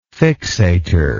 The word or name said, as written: fixator